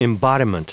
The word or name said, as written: embodiment